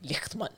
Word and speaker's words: Lichtman: tyskt uttal. Verkligen. Lichtman.